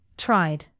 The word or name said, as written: tried